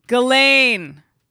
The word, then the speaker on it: Ghislaine: How do you say her name? – Ghislaine. – Ghislaine. Ghislaine.